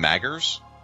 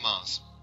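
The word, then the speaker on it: Magrs: It's actually pronounced Mars. – Oh, it is Paul Mars. I've heard his... Okay, I have heard that pronounced before. It's Paul Mars. – It's pronounced like that, yeah. – The G is silent, because that's common.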